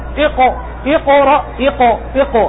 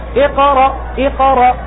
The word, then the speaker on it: اقرأ: شوف أنا ممكن أقول إقو إقو إقو إقو، هذا في تفخيم لكنه مبالغ فيه لأنه ساكن مرتبته هنا، أقول إقو إقرأ إقرأ، هذا كيف تقرأ؟